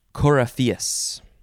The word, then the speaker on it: coryphaeus: I don't know what they do, but they are the coryphaeus. Yep, that's how you say it. Next word. Coryphae. C-O-R-Y-P-H-E-E.